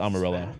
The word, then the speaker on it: Amarillo: Oh, it's Amarillo. It is the Spanish pronunciation, but yeah, it's Amarillo. – I love you. – It was the halfway Spanish pronunciation.